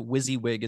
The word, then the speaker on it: WYSIWYG: I'm really glad that you guys explained to me that WYSIWYG is how you say it, because I was ready to just sit here and go WYSIWYG.